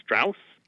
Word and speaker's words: Strauss: Or Strauss. – Thank you for pronouncing that correct.